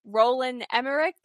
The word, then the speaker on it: Roland Emmerich: Roland Emmerich, I did say that right? – Yeah, you did.